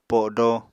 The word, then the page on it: podo: Apatani